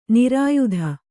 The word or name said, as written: ನಿರ್ಯಾತ